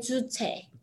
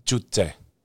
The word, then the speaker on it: Juche: Like Juche. – Juche.